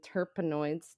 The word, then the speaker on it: terpenoids: And the moisturizer is CBD and terpene enriched with orange and tea tree, and it has some other trace cannabinoids and terpenoids. Did I say that right? Terpenoids? Yeah, that's right.